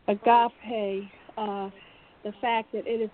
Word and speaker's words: agape: Okay, so let me talk about agape, which is the correct pronunciation of agape.